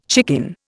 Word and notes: chicken: Indian

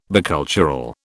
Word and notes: bicultural: British English

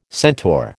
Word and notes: centaur: American English

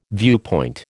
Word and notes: viewpoint: American English

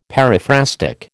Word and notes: periphrastic: American English

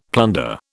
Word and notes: plunder: British English

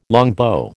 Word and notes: longbow: American English

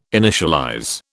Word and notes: initialize: British English

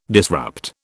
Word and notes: disrupt: British English

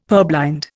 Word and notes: purblind: Indian

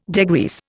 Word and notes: degrease: Indian

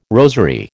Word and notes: rosary: American English

